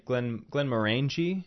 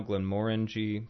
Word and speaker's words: Glenmorangie: But a lot of people, I've heard many people say Glenmorangie. – Right. – I have said that. I've pronounced it that way. I will say it now, Glenmorangie, since that's the appropriate pronunciation.